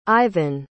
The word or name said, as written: Ivon